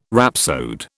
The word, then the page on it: rhapsode: British English